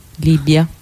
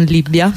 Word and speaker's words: Libia: Sì, diciamo che parlare del memorandum, in generale parlare di Libia, che anche se stiamo a Roma si scrive con una B, Libia, non Libia.